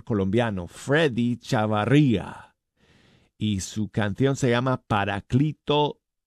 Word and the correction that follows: Paráclito: o Paráclito, perdón, lo dije mal, Paráclito Divino, aquí está.